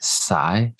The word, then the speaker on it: scythe: I believe it's pronounced sigh. – Sigh, thank you.